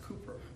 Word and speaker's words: Cowper: It reminds me of a hymn by William Cowper, spelled Cowper but pronounced Cooper.